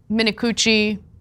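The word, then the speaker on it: Minicucci: Alaska Airlines CEO Ben Minakuchi, that is his name, that is how you pronounce it, we did double check, is furious with Boeing, which manufactures all of the planes in Alaska Airlines' fleet.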